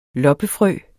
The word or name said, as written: loppefrø